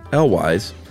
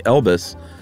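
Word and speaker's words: Cary Elwes: Elwise. I posted I had read on a Reddit AMA, his name sounds like Elvis, but what I meant to say was it rhymes with Elvis.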